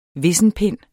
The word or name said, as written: vissenpind